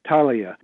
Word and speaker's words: Thalia: And I think it's pronounced, I'm sorry to correct you, but I think it's, they corrected me, it's Thalia, with a silent H, the Thalia String Quartet.